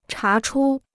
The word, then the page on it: 查出: chá chū